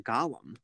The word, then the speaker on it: Gollum: No, the name is pronounced Gollum. – Gollum. – Yeah, it's not Gollum.